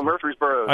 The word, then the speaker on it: Murfreesboro: Oh, Murfreesboro.